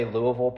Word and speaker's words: Louisville: I met someone this morning that said they know how to say Louisville properly, where you just blur all the vowels together. That is how you say it.